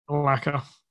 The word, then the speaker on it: Malacca: How do you say it? – Malacca. – That's what I said.